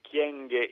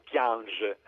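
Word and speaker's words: Kyenge: a parte la ministra dell'integrazione Kienge, nella sua lingua natia naturalmente congolese, o Kiange alla francese,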